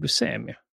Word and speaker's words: Buscemi: Jag lär mig aldrig hur man ska uttala det. – Steve Buscemi. Steve Buscemi.